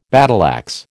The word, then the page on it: battleaxe: American English